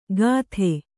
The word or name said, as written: ಕತ್ತೆ